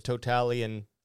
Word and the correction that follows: totalitarian: But what I mean to say is totalitarian tiptoe.